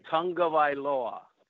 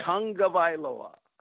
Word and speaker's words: Tagovailoa: Tagovailoa. Think tongue. – Okay. – Tagovailoa. – Tagovailoa.